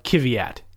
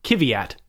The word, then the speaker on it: Kvyat: I'm trying to go with my Ted Kravitz pronunciation of Kvyat. He has, it's three syllables, emphasis on the first one, Kvyat. It's a lot, Kvyat.